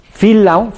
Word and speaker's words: Phil: El autor de esto, es un americano que se llama Phil Lound, que escribió un libro que se llama El dinero es mi amigo. Phil Phil, como si fuera Filiberto, nomás se escribe PH.